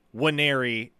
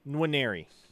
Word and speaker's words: Nwaneri: And I've tried to explain this before, but you got to kind of like roll the N into the W where you're not just saying Wanneri as if the N isn't there, but it's like a like you form the N in your mouth, but don't completely pronounce it. Nwaneri.